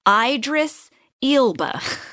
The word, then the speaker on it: Idris Elba: and his name is pronounced Idris Elba. – It's so ridiculous. – And they wrote it out, Idris Elba. – Yeah, they sounded it out.